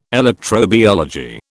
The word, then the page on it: electrobiology: British English